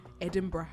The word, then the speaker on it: Edinburgh: How do you say it in your accent? – Edinburgh. – Edinburgh. That is correct.